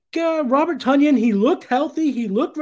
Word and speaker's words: Tonyan: And the first guy I'd like to talk about is Robert Tonyan. Rhymes with Funyuns.